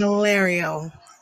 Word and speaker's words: Valerio: Mike Delario. I think I said it correctly. – Yes, you did.